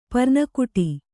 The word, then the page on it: ಪರ್ನಕುಟಿ: parna kuṭi